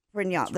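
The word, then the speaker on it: Pinault: Pignon.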